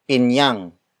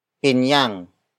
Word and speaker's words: pinyang: Word for word, it's pinyang kasing tamis ng pulot. Pinyang kasing tamis ng pulot. Pinyang kasing tamis ng pulot. The first word is pinyang. Pinyang. Pinyang. Pinyang is spelled as P-I-N-Y-A-N-G.